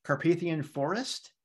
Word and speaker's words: Carpathian Forest: Carpathian Forest.